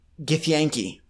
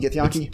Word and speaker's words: Githyanki: Githyanki. – I think it's Yonk. – I'll spell it. Yonki, Githyanki. – G-I-T-H-Y-A-N-K-I, right? – Yes.